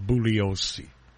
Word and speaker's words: Bugliosi: And, I'm going to keep using the G, even though I know it's incorrect. The correct pronunciation, though, for the record, is Bugliosi.